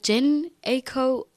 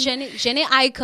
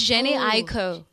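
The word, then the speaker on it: Jhené Aiko: okay, it's is it Jen Aiko or Jen? – Yeah, it's Jenny Aiko. I think it's Jenny Aiko.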